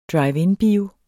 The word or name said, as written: drive-in-bio